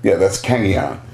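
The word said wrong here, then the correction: Kanegon: Or Kanagon. Sorry, Kanagon. I've been pronouncing it wrong for like 10 years.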